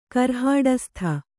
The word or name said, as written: ಕರ್ಹಾಡಸ್ಥ